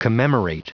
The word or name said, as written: commemorate